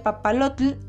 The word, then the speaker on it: papalotl: Otro por ejemplo es papalote, que se pronuncia papalotl y significa mariposa.